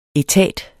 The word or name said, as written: etat